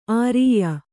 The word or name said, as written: ಆರಯ್ಯು